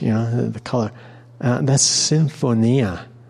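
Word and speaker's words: Symphonia (συμφωνία): Symphonia in Greek is how it's pronounced.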